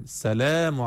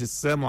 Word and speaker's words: السلام: المفروض انا لما اجي اقول للواحد اقول له السلام، لا لا لا، انطق اللام، السلام علي، السلام علي، مش السام علي، مش سامو عليكم يعني، لانه السام يعني الموت والهلاك.